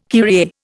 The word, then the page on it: Kyrie: Indian